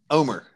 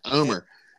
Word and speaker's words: Omer: Omer is how he pronounces it. Omer.